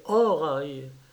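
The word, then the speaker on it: Horai: Les saisons, Horaï et l'armée, Eniotos et Oh, d'abord, si tu veux retrouver l'origine probable du mot Horaï, il faut le prononcer Horaï, suivant l'usage antique.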